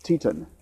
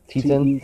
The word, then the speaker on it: Teten: Teton. – Teton? – T-E-T-E-N. – Okay. – Teton. – All right.